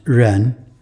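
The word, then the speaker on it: Ren (人): On the left-hand side, you see a person, pronounced Ren.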